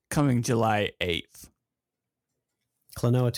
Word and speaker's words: Klonoa: What is this? Klonoa? Is that how you say it? Yeah, Klonoa.